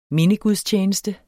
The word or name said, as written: mindegudstjeneste